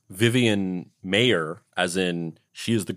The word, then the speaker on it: Maier: I think it's pronounced Maier. Maier!